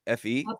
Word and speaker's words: fe: It doesn't work in English, but it works in Spanish, because faith is fe, F-E, pronounced fe, and coffee is cafe.